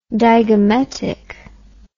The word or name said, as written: digametic